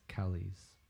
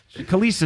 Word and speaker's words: Kelis: Is that how you say it? K-E-L-I-S? – I think it's Kelis. – Kelis? – Yeah. I don't think it's Kelly's. Like, this is Kelly's Toyota Honda.